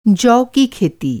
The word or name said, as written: खेती